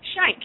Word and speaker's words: Schenck: It's Schenck. Yeah, rhymes with thank.